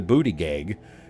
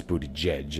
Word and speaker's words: Buttigieg: You might recall gay transportation secretary Pete in the booty gig. I say booty gig because it sounds funny. I know it's Buttigieg is the way he pronounces it.